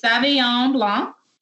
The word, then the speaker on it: Sauvignon Blanc: It is a Sauvignon Blanc. – Ooh, she pronounced it right.